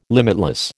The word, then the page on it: limitless: American English